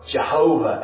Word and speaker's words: Jehovah: Or Jehovah Elohim. Another way of pronouncing that same Hebrew word.